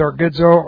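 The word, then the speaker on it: orgizo (ὀργίζω): And this is orgizo, O-R-G-I-Z, long O.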